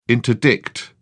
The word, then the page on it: interdict: British English